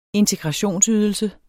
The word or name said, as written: integrationsydelse